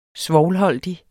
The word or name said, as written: SVOVLEN